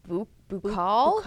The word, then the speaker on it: buccal: I think it's buccal. – Buccal? – B-U-C-C-A-L.